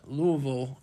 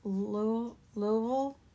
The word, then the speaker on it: Louisville: Louisville? – Louisville. – Louisville. – Yes, very good. – Yeah, apparently it's not pronounced Louisville. – It's not Louisville. I was told from someone who lives there, it's Louisville.